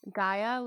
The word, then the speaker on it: Ghia: Gaia, like the goddess of the earth? – Yeah, but it's spelled G-H-I-A, and it's just launched like literally last week.